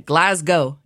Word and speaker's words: Glasgow: Oh, no, no, pronounce, let's try to not be American. We'll pronounce it right. Glasgow is what they would say.